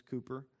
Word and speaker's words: Cowper: It's spelled and looks like Cowper, but it's Cooper, which contains very helpful truths for times when kingdom vision is waning.